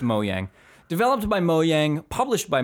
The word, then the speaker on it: Mojang: No, but how do you pronounce the developer of Minecraft? – Oh, Mojang. – You say Yang, okay.